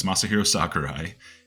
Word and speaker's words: Masahiro Sakurai: And I hope I said that right. Yes, Masahiro Sakurai.